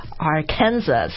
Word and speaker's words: Arkansas: 然后你看他这个拼写的话，the spelling is actually Arkansas. – A-R-K-A-N-S-A-S. 所以你看它，你就很想读 Arkansas, 因为美国确实有一个州，有一个 state 叫 Kansas. – Kansas, right. – 就是 K-A-N-S-A-S, 对不对？ – 对。 – 但是 A-R-K-A-N-S-A-S is not pronounced Arkansas. – No, it's Arkansas. – Arkansas, 所以后面那个 SAS 不发音的，就是好像 SAL 那样的，对吧？